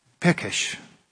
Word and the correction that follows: Pesach: unnskylda, Pesach, jeg uttaler seg 100%, men cirka det.